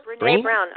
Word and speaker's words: Brené Brown: Brené Brown? – Oh, Brené Brown.